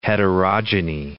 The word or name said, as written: heterogamety